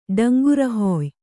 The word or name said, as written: ಡಂಗುರಹುಯ್ಯು